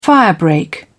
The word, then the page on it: firebreak: British English